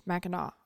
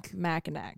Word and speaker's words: Mackinac: Anyway, like just before we started, I'm sitting here, like 2 minutes before we started, I found out that it's spelled Mackinac and it's Mackinac. I absolutely would have recorded this entire episode. – Mackinac.